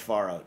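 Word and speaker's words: farro: I pronounce it Faro, even though I know it's pronounced Faro, just to tick her off. Is it? Because I keep hearing both. – It's Faro. – It's Faro. – All right. – Anyway, but like when you use a foreign ingredient that's not really fully like Americanized yet, you use the original pronunciation until proven otherwise.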